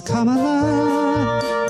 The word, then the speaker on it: Kamala: It's Kamala. The Biden campaign will see a nice bounce now, as lots of girls and women say ta-da. At last her name we're learning to pronounce now. It's Kamala. Kamala will help Biden's appeal improve.